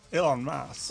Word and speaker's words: Elon Musk: ¿Musk? ¿Musk? ¿Cómo se dice? Es un apellido medio raro. – Para mí es Elon Musk.